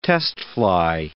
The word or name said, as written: test-fly